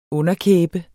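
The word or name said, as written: UNDERKÆBER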